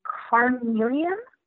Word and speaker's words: carnelian: That one's orange, and the crystal associated with that one, I believe it's pronounced carnelian. C-A-R-N-E-L-I-A-N.